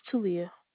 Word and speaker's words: Telia: 1-2-E? – Yes, ma'am. – E. Okay, what is your name? – It's Talia. – Spell that for me, sweetie. – T-E-L-I-A.